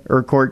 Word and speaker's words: Urquhart: Is it Urquhart? – Sure. – Okay, Urquhart Castle. Yeah, I believe that is how you say it.